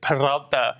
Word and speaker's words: Peralta: David Peralta. – Roll that R. Let's hear it. – David Peralta. It's Peralta.